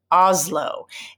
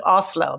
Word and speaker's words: Oslo: They said, I can't stand when Americans pronounce it Oslo instead of Oslo. I'm like, oh yeah, I guess I do say Oslo. But I also think it's because how we hear it, because the S is a little harder, right? It's like an Oslo. And so as an American, we will transfer that to Z, you know, but that's, I'm like, oh yeah, yeah, yeah.